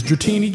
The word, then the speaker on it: Dratini: As most of you already know, the proper pronunciation is Dratini, Dragonair, and Dragonite.